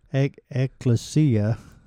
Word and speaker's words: ecclesia: It's not Trinity Baptist Church, but it is a great gathering, ecclesia.